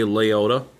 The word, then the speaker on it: Liotta: We have to discuss about it because of the most recent uh, departure in our lives, Ray Liotta. Am I saying it correctly? Yeah. Yes, you got it right.